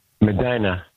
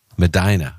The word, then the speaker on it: Medina: Medina. – Medina, okay.